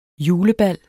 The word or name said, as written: julebal